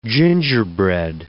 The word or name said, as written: gingerbread